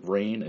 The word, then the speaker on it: Reyn: Just to clarify, Rain is spelled R-E-Y-N.